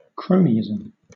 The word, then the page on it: cronyism: British English